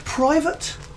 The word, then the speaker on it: Privet: Right, chaps, is this pronounced private? – Yes.